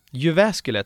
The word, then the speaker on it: Jyväskylä: Jyväskylä, tack.